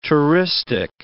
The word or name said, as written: touristic